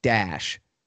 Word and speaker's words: Daesh: In Arabic, the acronym is pronounced DASH, D-A-E-S-H kind of thing.